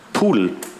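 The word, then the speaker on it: PDAL: Da ist einmal die Software Actinia zu nennen, dann die Software Graskis und die Software, wer weiß, wie die unterste Software ausgesprochen wird? Pudel, interessanterweise, auch wenn sie... Warum? Kleine Fußnote am Rande. Es gibt Ogre und es gibt Goodle. Das sind zwei klassische Bekannte. Goodle heißt Gedal, was wir alle als Gedal kennen. Und das soll sich darauf reimen. Goodle, die Schöne und das Biest. Ogre, Fiona, vielleicht schon mal gehört. Pudel, spricht man es aus.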